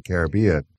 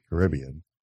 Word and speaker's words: Caribbean: I was trying to get you to say it the way you would normally say it, because some people say Caribbean and some people say Caribbean.